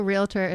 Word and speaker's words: realtor: Also, realtor is pronounced realtor. – Realtor. – Realtor. There's no in it.